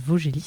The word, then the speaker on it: Vosgelis: Alors une dernière petite question, ça s'appelle Vaugelis, ça s'écrit comme les Vosges, donc j'imagine c'est que le département ?